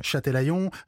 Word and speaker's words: Châtelaillon: Alors, ces petits trains proposent aux touristes les visites de plusieurs belles communes du coin, comme Royan, Sainte, Châtelaillon... si je prononce bien, oui, bien prononcé. – Châtelaillon, Saint-Georges de Didonne.